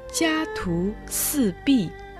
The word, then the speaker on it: 家徒四壁: Сейчас мы разберем это выражение – Слово «ся» произносится первым тоном. Оно означает «семья». – «Ту» произносится вторым тоном. Означает «только», всего «только», «лишь». – «Сы» произносится четвертым тоном. Означает «четыре». – «Пи» в четвертом тоне означает «стена». Все вместе читается «тя-ту-сы-пи», то есть «жизнь в четырех голых стенах».